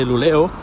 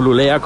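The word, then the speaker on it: Luleå: Segunda noticia es que en la Universidad de Luleo, no lulea como en algunos sitios he leído, luleo, a ver que es una A con un puntito, un topito encima de la A, pero se, pronuncia O.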